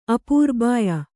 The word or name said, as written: ಅಪೂರ್ಬಾಯ